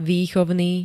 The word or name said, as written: vychovny